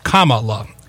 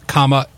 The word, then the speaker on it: Kamala: The favorite choice, I mean, especially among me and you, was what we now know is pronounced Kamala. Kamala. Not Kamala or Kamala.